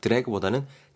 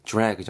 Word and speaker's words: drag: 디아 사운드 발음하실 때 우리는 보통 왜 드래그 한다 그러잖아요. 마우스로도 우리 왜 드래그 한다 그러죠. 근데 디아 사운드 약간 주 정도로 발음해 주시면 조금 더 클리어할 것 같아요. 그래서 드래그보다는 drag 정도 발음하시면 될 것 같고요.